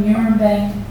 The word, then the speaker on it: Nurembang: "I was born on Nurembang. Can you hear it? Nurembang. If you say it right, it hits the back of your mouth and you should taste blood in your words.